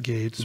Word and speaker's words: Gaetz: Carlson went on to say, one of the few people noticing AMLO's revelation was Congressman Matt Gaetz of Republican Florida. – I think you pronounce it Gates.